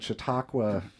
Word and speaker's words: Chautauqua: And you guys are coming through Fort Wayne as part of the Chautauqua Tour.